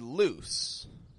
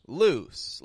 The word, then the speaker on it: loose: Many consonants are also voiced sounds, like l, mm, But there are also many consonant sounds that are unvoiced, also called voiceless, like s, When I pronounce these, I only have air coming through. Now, when we're pronouncing the word loose, loose, like L O O S E, loose, I have a loose shoelace.